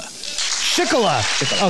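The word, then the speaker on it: Schickele: Shickola. Okay, at least we know how to say it now. I'm sticking with Shickley.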